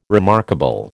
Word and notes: remarkable: American English

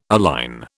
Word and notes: align: British English

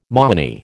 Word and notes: moony: American English

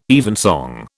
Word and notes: evensong: British English

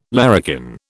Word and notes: larrikin: British English

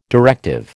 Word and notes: directive: American English

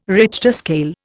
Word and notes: Richter scale: Indian

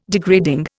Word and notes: degrading: Indian

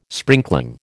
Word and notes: sprinkling: American English

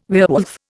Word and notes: werewolf: Indian

